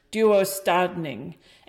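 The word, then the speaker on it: döstädning: And I don't speak Swedish, but the internet says it's pronounced duostadning, and that actually means death cleaning in Swedish.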